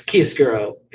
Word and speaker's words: chiaroscuro: But no, the big word, one of the big words would maybe be chiaroscuro, which is an Italian word. – Let me Google that. – Overpronouncing. It's chiaroscuro.